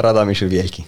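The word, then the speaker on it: Radomyśl Wielki: se la pronuncio bene si chiama Radomisz uh, Wielki. – Eh, Radomisz Wielki.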